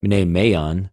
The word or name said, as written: μνημειον